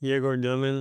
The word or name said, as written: Egor Demin